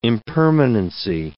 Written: impermanency

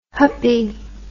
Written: huppie

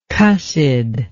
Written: cussedness

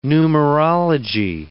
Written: numerology

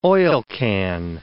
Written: oilcan

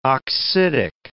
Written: oxidic

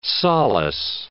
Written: solace

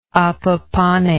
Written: apapane

